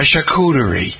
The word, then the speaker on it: charcuterie: Jim, maybe Jim will put out a charcuterie. Did I say that right? Yeah, a charcuterie.